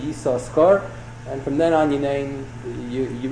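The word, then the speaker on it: Yisachar (יששכר): A strange, startling thing, in most shuls, when the Baal Koreh reads Kriyas HaTorah, so we normally pronounce the name Yisachar, you only pronounce one shin of the two Yisachars.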